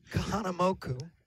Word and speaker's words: Kahanamoku: About Duke Kahanamoku.